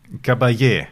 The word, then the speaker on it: Caballé: Ähm, boah, Montserrat Cabal. Nein. Caballé. Caballé.